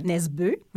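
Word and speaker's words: Nesbø: Nesbeu, parce que c'est comme ça qu'on doit le prononcer.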